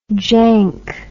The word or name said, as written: jank